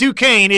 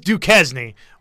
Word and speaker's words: Duquesne: No, it's not Duquesne. It is Duquesne. We have the correct pronunciation.